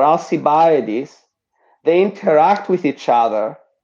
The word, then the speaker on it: Alcibiades: Another is Alcibiades, or some in English pronounce him as Alcibiades.